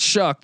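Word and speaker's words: Shough: And the quarterback situation, very interesting, as Alan Bowman transfers out to Michigan, but they bring in Tyler Shuck or show, I believe it's Shuck the way it's pronounced, but You know what? I see a tweet from 2017 where Tyler himself says it's pronounced Shuck.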